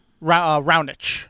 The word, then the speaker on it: Raonic: Raonic is Raonic. How he pronounced it.